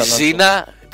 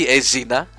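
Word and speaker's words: Xena: Η ΖΙΝΑ Ζήνα προφέρεται Ζήνα, όχι Εζίνα.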